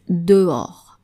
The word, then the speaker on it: dehors: So basically, the challenge here with dehors is that there's an H in the middle of the word. The final S is silent, of course. But when you listen to it, there's de and or, dehors. So two vowel sounds that are following each other.